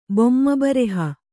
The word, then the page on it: ಬೊಮ್ಮಬರೆಹ: Kannada